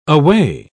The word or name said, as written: away